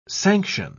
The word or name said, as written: sanction